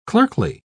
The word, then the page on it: clerkly: British English